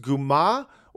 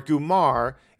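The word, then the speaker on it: comare: It's usually pronounced Guma or Gumar in American English, and it means like a mafia girlfriend.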